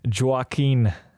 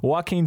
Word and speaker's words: Joaquin: Joaquin. No, wait, hold on, hold on. I didn't say that right. Joaquin Phoenix, um, a failed comedian who wants nothing but to bring laughter and joy to people's lives.